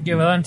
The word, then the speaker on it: Gévaudan: Y esto ocurrió en Francia, en la localidad de, no lo puedo pronunciar, Gévaudan, algo así. Uh-huh, Gévaudan, sí, sí.